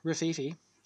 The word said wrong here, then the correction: Rififi: Rifi. FI. Yeah. – You sure it's not called Rafiki? – No, it's Rififi. – Nothing's coming up in Letterboxd, though. – Hang on. Yeah, R-I-F-I-F-I.